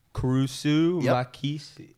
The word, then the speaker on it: Kurisu Makise: Kurisu Makise.